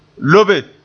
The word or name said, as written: lobe